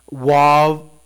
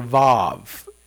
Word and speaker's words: Vav: But each sentence in this psalm begins with the Hebrew letter Wav or Vav, and it can be pronounced either way.